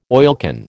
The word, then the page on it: oilcan: American English